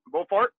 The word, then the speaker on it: Beauport: But before I get to there, your first year in, am I saying it right, Beauport? – Yeah, Beauport, yeah.